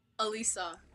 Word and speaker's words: Alyssa: Alyssa.